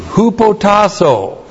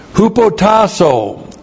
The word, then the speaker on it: hupotasso: Hupotasso. H-U-P-O-T-A-S-S long O. Military term. Used by the Apostle Paul again and again and again. Here it's used by Peter. Hupotasso.